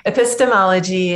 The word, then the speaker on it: epistemology: but epistemology? How do you pronounce it? – Yeah, epistemology is this.